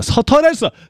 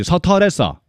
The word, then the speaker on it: 스트레스: 서터레스. 우리 쪽 발음이지. 서터레스. 그죠? 서울, 그 웃기죠? 서울은 서울이라 그러고, 스트레스는 서터레스라 그러고, 그죠?